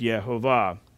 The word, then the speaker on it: Yehovah: However, many scholars have historically believed that the pronunciation is Yehovah. Hebrew normally places the stress of a word on the last syllable.